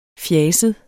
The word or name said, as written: fjaset